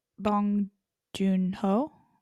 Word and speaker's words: Bong Joon-ho: Well, who beat out her was Bong Joon-ho, am I saying that right? – Yeah.